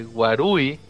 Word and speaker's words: Warui: Y ya por ahí Medina nos explica que Waluigi es un juego de palabras, que Warui, R pronunciado como L, es lo mismo en japonés y significa malo.